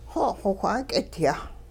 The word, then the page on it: xo' xowh 'aak'idyah: Hupa